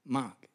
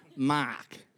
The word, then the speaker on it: Mark: We don't pronounce the R in words. In fact, not only don't we pronounce the R, we swap it for an extra A. So my name is spelled, in America, spelled M-A-A-K, Mark.